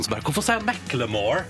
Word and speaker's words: Macklemore: At det skal uttales Macclemore.